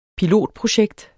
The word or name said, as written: pilotprojekt